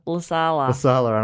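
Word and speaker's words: Lassila: Olympic gold medalist Lydia Lasilla. – Lasala. – And I said it wrong Lassala. On the show. – Lasala. I think it's Lasala.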